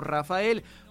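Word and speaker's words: Raphael: Mencionarles que bueno, cuando usted busca a Rafael, sin duda, usted sabe que su nombre se escribe con PH, aunque se menciona como Rafael, pero no siempre fue así.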